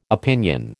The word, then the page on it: opinion: American English